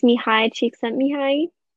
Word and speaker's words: Mihaly Csikszentmihalyi: Yeah, hopefully I can do it justice, but it's Mihaly Csikszentmihalyi who wrote Finding Flow in 1990.